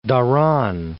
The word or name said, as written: Dhahran